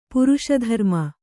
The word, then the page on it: ಪುರುಷಧರ್ಮ: puruṣa dharma